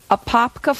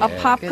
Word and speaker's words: Apopka: So Tim, Ethan, and Michelle of Apopka, Florida. Did I say that right? Liz is nodding. – Did. – Apopka, Florida.